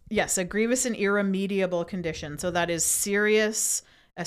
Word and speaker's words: irremediable: Side note, I was also pronouncing it irremedial. Oh. It's irremediable. It's a harder word to say.